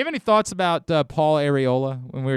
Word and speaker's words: Areola: Also, by the way, it's technically pronounced Areola.